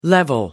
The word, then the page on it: level off: British English